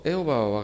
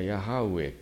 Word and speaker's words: ヤハウエ: はい、ですから、まあ、一応エホバは我が牧者なりと書いてありますけど、これは正確にはヤハウエという名前はヤハウエということですね。